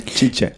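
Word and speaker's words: Tite: Hur uttalar man det? Är det Chiche? Chiche. – Ja, jag har lärt mig Chiche.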